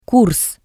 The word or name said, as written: kurs